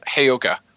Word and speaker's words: Heyoka: You might get to see the Hoyokia. Is that how you say it? – Dang it, Heyoka.